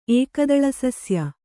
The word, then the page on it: ಏಕದಳಸಸ್ಯ: ēkadaḷasasya